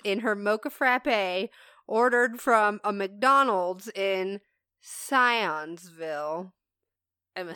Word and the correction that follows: Sissonville: Nope, I definitely did not say it right. Sissonville. I don't know. S-I-S-S.